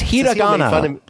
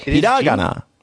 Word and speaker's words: hiragana: It's hiragana. You emphasized the wrong syllable, John. You said hiragana. Hiragana. That's how you said it. It sounded like stroganoff when you said it.